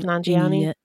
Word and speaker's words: Nanjiani: Nanjiani? – Yes.